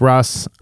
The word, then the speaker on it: Rós: You know, if he says Ross, I'm saying Ross.